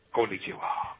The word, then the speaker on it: Konnichiwa: Konnichiwa. – All right, konnichiwa. Smelly. – Konnichiwa. When you're talking to the world, it's konnichiwa, bitches. – Only you. – Konnichiwa, bitches. – Konnichiwa, real quick. Konnichiwa, bitches.